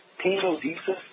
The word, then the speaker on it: tenodesis: It's called tenodesis, and don't even ask me how to spell it, but it's pronounced tenodesis.